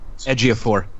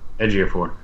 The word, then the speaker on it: Ejiofor: Chiwetel, and how's the last name? – Ejiofor. – Ejiofor. Chiwetel Ejiofor.